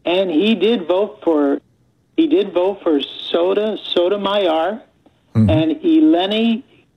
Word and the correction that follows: Elena: if I'm pronouncing her name right, Elena Kagan.